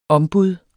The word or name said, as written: ombud